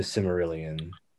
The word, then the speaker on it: Silmarillion: But like this Lord of the Rings show, it's not even based on like the Silmarillion or anything, right? It's like it completely pulled out of someone's ass. It's not Tolkien, right? – Yeah, the Tolkien family didn't give them rights to the Silmarillion.